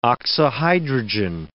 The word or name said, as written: oxyhydrogen